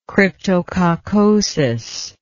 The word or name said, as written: cryptococcosis